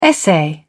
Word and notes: essay: British English

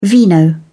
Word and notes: vino: British English

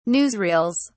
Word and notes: newsreels: British English

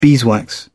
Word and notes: beeswax: British English

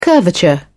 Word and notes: curvature: British English

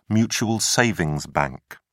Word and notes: mutual savings bank: British English

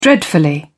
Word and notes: dreadfully: British English

